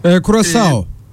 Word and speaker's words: Curaçao: Curaçao diye okundu, doğrudur umarım telaffuzum. – Curaçao.